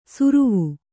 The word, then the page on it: ಸುರುವು: suruvu